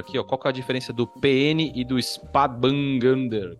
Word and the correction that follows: Spätburgunder: Como é que fala isso? É isso? Falei certo? – É o Spätburgunder ou o Pinot Noir?